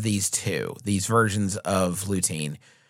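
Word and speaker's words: Lutein: And they have a trademark version of Lutean. Is that how you'd pronounce that? – Mm-hmm. Lutean.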